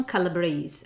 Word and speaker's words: Calabrese: First of all, the name Autumn Calabrese, okay? The right pronunciation is Calabrese because it's absolutely a family name that come from Calabria, that is a region of Italy.